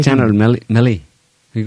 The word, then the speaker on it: Milley: Milley.